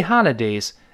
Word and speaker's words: holiday: 哎，We have three holidays，呃，三天的假。这个假期呢，英式发音当中 holiday，holiday for o o 这个字母 o for o，美音呢觉得费劲，由 o 又变成 r，所以读成 holiday，holiday，它是 three holidays，三天。